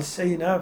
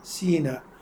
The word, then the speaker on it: سيناء: سيناء البلد المكان المعروف إلى اليوم معروف، سيناء معروف، يقال سيناء في قراءة ثانية سيناء بكسر السين، فيطلق يعني تلفظ بالوجهين سيناء سيناء، يعني نسبة إلى المكان المعروف.